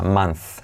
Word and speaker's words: month: La t de next no se dice next month la TH de month, como manzana pero sin ana, month, semiclave, month, ¿vale?